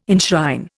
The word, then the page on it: enshrine: Indian